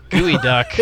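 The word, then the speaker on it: geoduck: It really is pronounced gooey duck.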